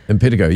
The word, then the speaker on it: impetigo: impetigo? – Impetigo, yeah.